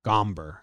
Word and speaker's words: Gomber: He was part of the Arenado trade, but Gomer. We say that wrong a lot. Everyone says. – Sure. – Gomber. I think you pronounced it. – Gomber. – Somber about it.